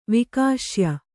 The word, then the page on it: ವಿಕಾಶ್ಯ: kannada